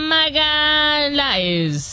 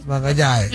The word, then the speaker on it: Magalhães: Gabriel Magalhães. – ¿Magalhães? – Magalhães. Es que se escribe Magalhães, pero Magalhães.